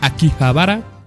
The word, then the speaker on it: Akihabara: Una demostración pública jugable de la versión beta se llevará a cabo el 25 de marzo en Akihabara.